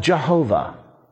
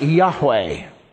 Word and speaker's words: Yahweh: The best we can do in English is to describe it as J-H-W-H. Now, that's not very easy to describe or to pronounce, J-H-W-H. Scholars differ as to how to point that or how to supply it with vowels so that it can be pronounced. The King James translators called it Jehovah. Today, most scholars would say something like Yahweh.